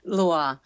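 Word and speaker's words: lwa: Mambo, as we talk about those religious spirits, we have to explain a little bit about Lua. Is that how you say it? I have to work on my pronunciations here. – Lua. – Yeah.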